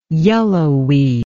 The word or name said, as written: yellowweed